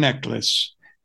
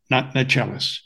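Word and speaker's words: Necheles: The lawyer mentioned twice pronounces her name Susan Necklace, not Nachelis, but I'll leave that.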